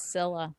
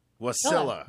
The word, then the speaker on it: Wasilla: It's Wasilla. – Thank you. Well, what is it? – Wasilla. – Wasilla.